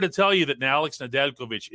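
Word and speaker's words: Nedeljković: Alexander Nedeljkovic is how you would say it exactly.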